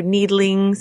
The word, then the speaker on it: Needling: The lovely Sarah Carlson, Andy Shear, Daniel Steer, Andrew Needling, sounds like Liebling, and Sam Forsyth.